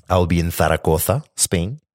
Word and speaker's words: Zaragoza: And so I have a packed schedule that includes evening tours of the museums and tourist sites in Zaragoza. You'll notice how I'm pronouncing it the correct way.